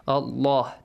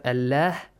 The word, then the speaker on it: Allah: Allah is pronounced heavily. Allah. This way, not Allah.